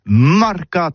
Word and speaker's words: mercat: c'est prononcé sur les marcat, avec un A, en gascogne toulousaine, bah, du côté, par exemple, de l'île des Baches, c'est l'île Jourdain, pour parler du marché, vous prononcez les marcat.